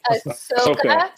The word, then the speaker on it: Ahsoka: You have the Scarlet Witch, who almost defeated Thanos by herself against Asana. I can't even say her name. Is Asana? – Ahsoka. Ahsoka Tano.